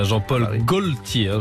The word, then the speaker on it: Jean-Paul Gaultier: Il va nous manquer, en tout cas, Jean-Paul Gaultier.